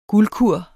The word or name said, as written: guldkur